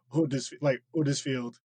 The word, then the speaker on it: Huddersfield: Yeah, and like I'm from, I live in a town called Huddersfield, but people from here do not pronounce the H. It's just Huddersfield. Mm-hmm. And that you just miss the H off completely.